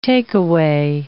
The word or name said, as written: takeaway